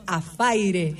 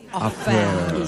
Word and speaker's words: affair: Además de esto, una vez que falleció No, chicas, Muñecas se muere porque nosotros decimos Afaire. Affair. Y Muñecas dice que tenemos que decir Affair.